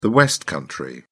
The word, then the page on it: West Country: British English